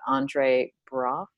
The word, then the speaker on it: Andre Braugher: Andre Braugher, yeah.